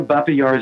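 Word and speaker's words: Babi Yar: So Baba Yar is the Russian name, and Babin with an N is the Ukrainian pronunciation.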